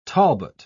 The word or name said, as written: talbot